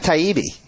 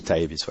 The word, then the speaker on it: Taibbi: We have Matt Taibbi of – that's how you pronounce it, right? – Right, right.